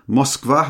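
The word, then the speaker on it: Moskva (Москва): And then I think I've got this is definitely a spy thriller, a book called Moskva, or Moscow really, pronounced as the Moscowese would pronounce it, I suppose.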